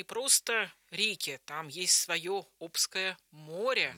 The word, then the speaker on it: Обское: И вы все верно сказали, у нас есть Обское море, Обское, Обское, все говорят по-разному, но правильно говорить Обское.